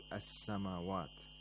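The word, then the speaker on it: as-samawat: Now, in the phrase rabbu samawat, we have a sun letter after the alif lam in the word as samawat. So the lam is not pronounced. Instead, the sun letter becomes mushaddad.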